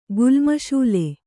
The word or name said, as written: ಗುಲ್ಮಶೂಲೆ